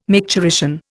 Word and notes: micturition: Indian

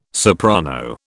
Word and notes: soprano: British English

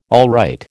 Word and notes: alright: American English

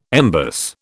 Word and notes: embus: British English

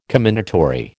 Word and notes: commendatory: American English

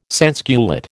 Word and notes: sansculotte: American English

